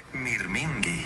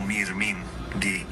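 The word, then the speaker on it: mirmingi (μυρμήγκι): Se dice mirmingi. Suena como mirmingi, más o menos.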